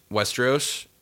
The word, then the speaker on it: Westeros: Westeros.